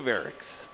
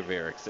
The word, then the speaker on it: Wirichs: Myself, Greg Hollenbach, Brian Freeman, Jay Parker, Chef Justin Brunson co-hosting with us this hour, and right now, Julia Wirichs. How are you? – I'm good. How are you? – Good to see you. Did I say that right, Julia? – Julia Wirichs, yeah. – Yep, Julia Wirichs and Petite Fleur.